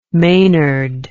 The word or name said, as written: maynard